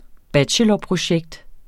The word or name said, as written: bachelorprojekt